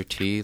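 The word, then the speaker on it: Ti: Yeah, just Yo Is This Racist on podcasts, and Andrew T, last name is spelled T-I.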